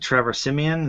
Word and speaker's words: Trevor Siemian: What is it, Trevor Siemian? Is that how you say his name? – Yeah, so he went to Northwestern, and I remember watching him in college.